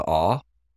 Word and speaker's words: awl: Is it just you leave off the L? – It's an aw. Aw.